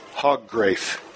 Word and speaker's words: Hogrefe: Caleb Hoggrafe is how it's pronounced. I asked the coach for two pronunciations. H-O-G-R-E-F-E. Hoggrafe. Hoggrafe.